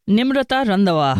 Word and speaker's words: Nimrata Randhawa: Nimrata Randhawa.